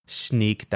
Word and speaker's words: Snyk: Just a week ago or less, Sneak, that's spelled S-N-Y-K, hot company we've been following for a while.